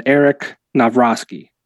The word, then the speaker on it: Novroski: Well, I'm joined by Andrew Blank and Eric Novroski. Did I say that right? – You nailed it.